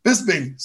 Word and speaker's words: Bisping: Bisping, sorry. Yeah. Dude, I'm a stuttering. – No, you said it right.